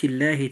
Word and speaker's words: الله: والحالة الثانية للام لفظ الجلالة، وهي ترقق إذا كان ما قبلها مكسورا. الآية: ولن تجد لسنة الله تبديلا.